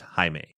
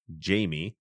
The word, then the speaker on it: Jaime: As I mentioned, you know, I've had a lot of years of Spanish courses, and that name in Spanish is pronounced Jaime. In English, you pronounce it Jamie.